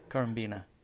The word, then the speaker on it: Currambena: It's pronounced Currambena. – Currambena, yeah.